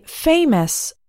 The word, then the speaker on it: famous: The first category is words ending in O-U-S. The O-U-S at the end of these words sounds like is. It does not sound like aus. We say famous.